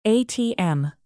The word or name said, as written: atm